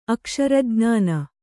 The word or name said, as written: ಅಕ್ಷರಜ್ಞಾನ